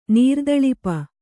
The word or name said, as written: ನೀರ್ದಳಿಪ